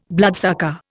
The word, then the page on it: bloodsucker: Indian